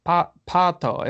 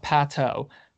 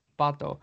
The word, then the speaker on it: Pato: Someone gave us the pronunciation. Pato. It was Moraima. Pato. Not Pato. – Yeah. – Shout out to Moraima for that one. Pato.